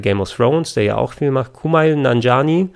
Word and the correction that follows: Nanjiani: äh, oder nee, Nanjiani wird es ja ausgesprochen.